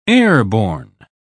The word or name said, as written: airborne